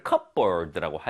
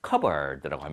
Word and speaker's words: cupboard: 근데 이거를 cupboard라고 하지 않습니다. cupboard라고 합니다. 즉 P 발음이 묵음이다 라고 생각하시면 됩니다.